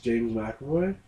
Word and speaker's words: McAvoy: Like, it's just, it's all bad, but one of my favorite actors actually is James McAvoy. That's how you pronounce his name.